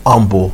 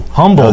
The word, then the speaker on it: Humble: They play Humble Kingwood Park in their state semifinal, and then it's Humble. – Humble. Okay. Humble. – I work in that region. They take it. Yeah. The only word, and I think in the English language, that the H is silent.